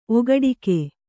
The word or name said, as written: ಓಗಡಿಕೆ